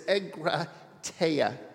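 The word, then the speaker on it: egrateia (ἐγκράτεια): The Greek word is egrateia. You can try it if you want. Try it. Egrateia. Teia. People argue about how to say it, but there you go.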